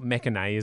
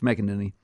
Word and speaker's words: McEnany: It's not McEnany, is it? – It's McEnany.